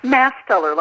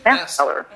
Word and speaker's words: Mastaler: Mastaler, like going to church and... Mastaler.